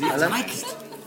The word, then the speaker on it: Zeitgeist: We can all remember where we were when we first heard them, and they're the sort of Zeitgeist of a generation. – The Zeitgeist. – Well said. Yeah. – Zeitgeist. – Zeitgeist. – Yeah. That's how the Germans say it.